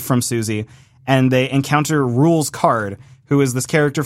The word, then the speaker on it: Rouxls: I also like that they called his battle theme here, it's pronounced Rules, because that was a big debate when Chapter 1 came out that no one knew how to pronounce his name. It's spelled R-O-U-X-L-S, as in like a curry roux, for example.